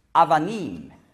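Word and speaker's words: Avanim (אבנים): Nein, Avanim. Das B ist weich ausgesprochen, Avanim, aber man schreibt Abanim, ja?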